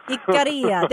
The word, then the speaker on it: Ikaria: And, of course, I'm saying Ikaria, and you're saying it so much more in such a better way. Tell me how to pronounce it one more time.